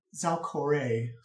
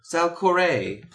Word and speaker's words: Zalcore: "Yeah, Your Majesty Zalcora. I come seeking..." – And she says, hold her pen. "Zalcore." – "Zalcore. – Please pronounce it appropriately."